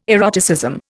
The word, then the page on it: eroticism: Indian